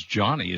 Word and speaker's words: Jony: But I guess his name is Johnny. Is that how it's pronounced? Ivy? – I don't know. I've never met him. – Yeah. – Johnny Ivy. – Either John or Johnny Ivy. It's spelled J-O-N-Y.